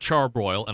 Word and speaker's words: Char-Broil: I am a well-known, longtime, staunch critic of Charbroil, and I'm saying it right this time, not like we did the last time.